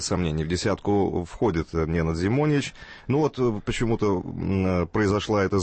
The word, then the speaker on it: Зимонич: меняет Ненад Зимонич, так, как выясняется, правильно произносить фамилию этого теннисиста.